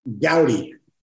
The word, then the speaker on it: Goudie: You sound like an an Alex Goody, you know, inner inquiry. Gaudi, did I mispronounce your name? Oh my gosh. Mm-hmm. What is it? – Everybody mispronounces it. – Okay. Gowdy. Gowdy.